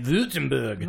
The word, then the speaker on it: Württemberg: But Carmela, in Württemberg, Germany, I think that's how you say it, Württemberg. – Wow, that's pretty good.